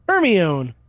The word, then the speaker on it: Hermione: Hermione. – Permione? – I heard a lot of people pronounce Hermione Hermione until they heard it. In the movie.